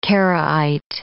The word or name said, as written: Karaite